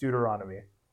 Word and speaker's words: Deuteronomy: the, it is pronounced Deuteronomy.